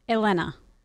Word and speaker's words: Elena: Your daughter is Elena. – Elena. – Which is spelled? – E-L-E-N-A. – Okay. My wife, her name is Elana. E-L-A-N-A.